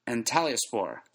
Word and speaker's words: Antalyaspor: Antalyaspor.